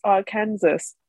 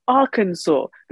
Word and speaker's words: Arkansas: And then I looked on Twitter and I subsequently saw a ton of, it's Arkansas, not Arkansas.